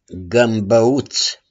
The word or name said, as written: GĂMBĂUŢ